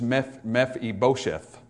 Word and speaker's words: Mephibosheth: Now when, we oftentimes pronounce this name Mephibosheth. I do that a lot of times as well, just because it's easier to do so. But the correct pronunciation is Mephibosheth, which is a little bit different than Mephibosheth. It'll mess up a couple songs, but it's actually pronounced Mephibosheth.